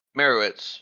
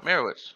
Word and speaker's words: Meyerowitz: It's very hard to pronounce. It's Mayer, and then O, and then Witz. – Meyerowitz. Yeah. Like Meyerowitz.